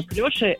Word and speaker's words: клёши: Клёши – это слово неглагольное. – Так. – Расклешённый.